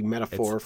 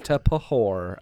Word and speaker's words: metaphor: It literally means it's a metaphor for a wound. – A metapahor, as it's pronounced if you read the word, not knowing that P and H go together as an F.